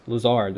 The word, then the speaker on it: Lazard: How do you say this? Lazard. That's it.